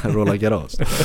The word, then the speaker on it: Roland Garros: Du kör lite, man märker ditt Counter-Strike-uttal där. Jag tror fransmännen själva säger Roland Garros. – Roland Garros.